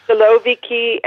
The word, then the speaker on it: siloviki: Is it Siloviki and Pitersky? So, I don't know if I've got that right. – Siloviki and Pitersky. – Thank you. – And Siloviki has been used so commonly now that it's almost taken as a given, but people, you're right, there's a good question.